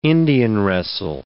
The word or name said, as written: Indian-wrestle